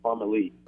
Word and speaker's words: Parmalee: It's Parmalee, with the emphasis on the Lee.